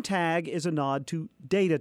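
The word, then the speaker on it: TTAGG: It's T-T-A-G-G, and that's pronounced tag.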